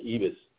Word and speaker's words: Ribas: She bloodied up, I know it's spelled R-I-B-A-S, but they kept pronouncing it Ibas.